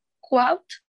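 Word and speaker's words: Coatue: fue que a una nueva ronda de financiamiento por Quout, si, así se pronuncia, que son los que están detrás de las empresas grandísimas como TikTok y Bitso.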